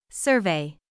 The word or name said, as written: survey